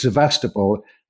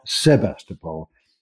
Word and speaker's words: Sevastopol: And then he confirms, yes, it's Sevastopol, not Sebastopol. And he blames early translations of War and Peace for this.